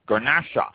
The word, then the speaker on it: Garnacha: Garnacha, G-A-R-N-A-C-H-A, Garnacha.